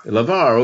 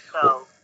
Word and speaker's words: LaVarre: I do say, Dad called it LaVarre, okay. Well, it's good. – Back in France, they thought it was LaVarre.